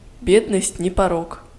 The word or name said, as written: бедность не порок